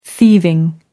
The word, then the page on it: thieving: American English